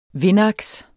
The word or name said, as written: vindaks